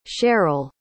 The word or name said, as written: cheryle